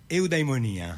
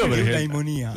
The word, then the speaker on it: Eudaimonia: Eudaimonia. – Ok, lo vedi come lo pronuncia, anche con gli accenti giusti. – Eudaimonia.